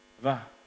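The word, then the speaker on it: Vav: They're pronounced Yod, Hey, Va, Hey, Yod, Hey, Va, Hey.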